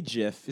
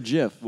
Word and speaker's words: GIF: Is it GIF or JIF? – Well, he laid it out in 2013 in an interview with the New York Times. He said that the Oxford English Dictionary accepts both pronunciations, but it's GIF.